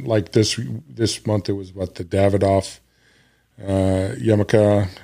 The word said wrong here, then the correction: Yamasa: I'm probably saying that wrong. – Yamasa. – Yeah.